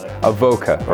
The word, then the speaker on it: Avoka: Yeah, you got the Avoka bit wrong. – Oh, I see. You knew I'd pronounce something wrong. Avoka, right. Yeah, it's Avoka.